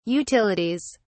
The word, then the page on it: utilities: British English